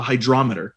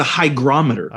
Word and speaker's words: hygrometer: I've been calling that my whole life a hydrometer. – Sure. I remember that. – It's a hygrometer. – Oh. – There's no D in there. It's hard G, hygrometer.